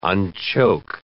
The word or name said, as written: unchoke